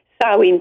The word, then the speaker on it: Samhain: Samhain pronounced, spelled S-A-M-H-A-I-N-E.